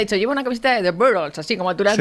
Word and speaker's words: The Beatles: Aquí en pero pronúncialo como lo pronunciaste. – La banda se llama The Beatles.